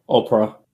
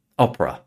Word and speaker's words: Opera: is it Opera or Opera? The browser maker. – Yeah, Opera. – Opera, yeah. Okay, so they were acquired by Opera Software earlier this year.